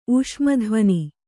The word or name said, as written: ಊಷ್ಮಧ್ವನಿ